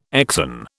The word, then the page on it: exon: British English